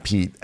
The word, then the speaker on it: Peet: So There's an internet personality there named Pete, and his name is spelled P-E-E-T, like Pete's Coffee.